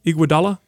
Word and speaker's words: Iguodala: Iguodala? – Yes.